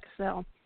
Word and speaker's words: Casals: With an S or no S? Casals? – With the S, Cassell.